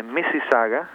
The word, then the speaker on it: Mississauga: Century la escribimos con C-E-N-T-U-R-Y Avenue. En la ciudad de Mississauga, es un nombre indígena, M-I-S-S-I-S-S-A-U-G-A, en la provincia de Ontario. Se escribe así mismo como suena.